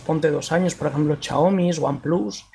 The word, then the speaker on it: Xiaomi: Por eso, fíjate que, la semana pasada le di mucha importancia a que el Redmi Note 7 de, Xiaomi, que es como decía Hugo Barra que había que pronunciarlo, eh, Xiaomi, como Xiaomi de Money, eh, que hubieran ya, mm, le hubieran flasheado Ubuntu Touch.